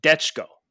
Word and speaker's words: Dechko: Uh, it's properly pronounced Detchko. Yeah, I wouldn't have got that. But D E C H K O is how he says it's pronounced.